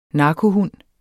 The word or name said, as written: narkohund